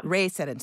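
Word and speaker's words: Rhea Seddon: Judy Resnick, Anna Fisher, Kathy Sullivan, Shannon Lucid, and Rhea, is it Seddon? Is that how it's pronounced? – Ray Seddon, yeah. – Ray Seddon, sorry, Ray.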